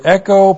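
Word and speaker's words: Echo (ἔχω): This is the present active participle of the verb echo plus the accusative singular of diakonia with a definite article plus the accusative singular of hutas. Echo. Echo. E-C-H, long O, echo.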